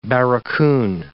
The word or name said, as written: barracoon